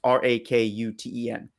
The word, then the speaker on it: Rakuten: And then Rakuten, I think is how you pronounce the third one. It's Rakuten.